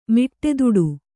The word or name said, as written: ಮಿಟ್ಟೆದುಡು